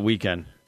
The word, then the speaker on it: Weeknd: It's spelled Weeknd without the E and end. – Oh, I got you. Okay. – But it's still, yeah, you still it. Pronounce it Weeknd.